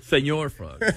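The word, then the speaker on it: Señor Frog's: Senor Frog. – Whatever. I didn't have a problem with the way you said it out. – Well, he's in Mexico. You know, you gotta pronounce it right.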